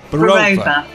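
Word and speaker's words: Burova: Do you say Barova? How do you say it? – Barova.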